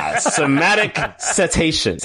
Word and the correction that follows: semantic satiation: The correct pronunciation of this word is semantic satiation.